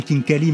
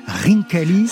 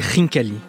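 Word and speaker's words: rinkali (ხინკალი): Et ça commence avec un véritable coup de foudre pour un ravioli, ce ravioli géorgien que l'on nomme, alors dans le reportage on disait khinkali, mais si j'ai bien compris on prononce rinkali. – C'est rinkali.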